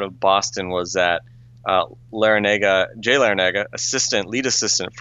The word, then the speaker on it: Larrañaga: Larrañaga. It's just Larrañaga. Okay, that's the boring pronunciation.